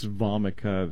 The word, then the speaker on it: Vomica: And that's spelled N-U-X. And the second word is almost like vomit. It's Vomica, though. V-O-M-I-C-A.